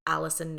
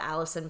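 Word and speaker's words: Allyson: My full legal birth certificate name is Allison Nicole Ura. So that is who I am. That is me. It is actually really, I love the Allison part because it's A-L-L-Y-S-O-N, which not too many people spell it like that.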